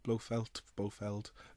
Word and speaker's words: Blofeld: Well, that's the interesting thing about this one, is it has not only one actor who played Blofeld. Am I saying that name wrong, right? – Blofeld.